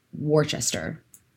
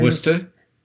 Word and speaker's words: Worcester: A man from Worcester, and this is not Worcestershire. This is just Worcester. There's no sure on the end of it. Before you say, you're Worcester? – Yeah.